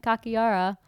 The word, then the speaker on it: Kakihara: I was so close. The H is silent. Kakihara. Daddy searches for his missing boss.